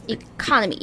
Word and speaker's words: economy: So it's at the second syllable? – Economy, economy.